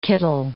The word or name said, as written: kittle